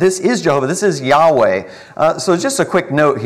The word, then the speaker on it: Yahweh: And so if you look at the word YHWH to try to pronounce God's name, well, kind of the best you can do without having any vowels to work with is just kind of say like Yahweh.